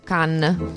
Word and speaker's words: Cannes: Cannes o Cannes? – Cannes.